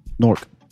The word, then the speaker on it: Newark: Newark. – Newark. – N-O-R-K. – N-O-R-K. – Newark. – And that's how you say Newark. – That's how they say Newark. – Yeah, we say it one syllable.